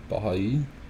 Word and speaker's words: Baha'i: B-A-B-A-H-A-I, Baha'i.